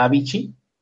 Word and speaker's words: Avicii: Mm, un inquietante documental muestra a Avicii, quedamos que se llama, que se pronunciaba Avicii, en lucha por mantener sus ojos abiertos.